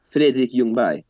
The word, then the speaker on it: Fredrik Ljungberg: Fredrik Ljungberg.